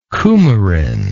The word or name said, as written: coumarin